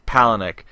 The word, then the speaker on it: Palahniuk: Palahniuk. – Name? Palahniuk.